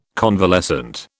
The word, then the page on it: convalescent: British English